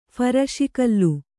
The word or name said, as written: ಫರಸುಗಲ್ಲು